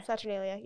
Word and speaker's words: Saturnalia: Saturnalia, yeah.